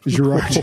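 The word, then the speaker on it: Gérard: They do say his name like Gary.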